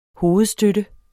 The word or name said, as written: hovedstøtte